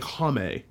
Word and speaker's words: Homme: Home. I think it's just Josh Homme. – I've heard it pronounced like Homme.